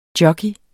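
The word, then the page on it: JOCKEYEN: Danish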